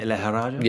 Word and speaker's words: Lejarraga: Right, you're going to talk about this one, Joel, because former European lightweight champion Kerman Lehraja, is that how you say it, Lehraja? – Yeah, it is, yeah.